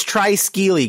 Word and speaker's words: Triskele: Treskeli. How would you say it? – Yeah. It's Triskeli because there's three of them. So try.